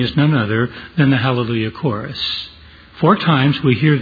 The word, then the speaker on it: Hallelujah: It is spelled Alleluia in our text, but actually the A has an aspirate before it, which gives it an H sound. So actually the word is Hallelujah.